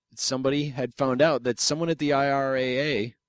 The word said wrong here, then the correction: RIAA: or RIAA,